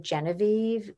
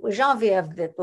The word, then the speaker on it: Geneviève: Nobody told me it was Geneviève. I thought, is it Genevieve? How is that going to be? And I walked into his office, and before we did the scene, he and the stage manager and I did the scene. He said, well, Geneviève, blah, blah, and Geneviève. I said, oh, Mark, you have just told me so much about this character just by the way you pronounce her name.